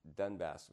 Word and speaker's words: Donbass: so now this new invasion is for the Donbass region.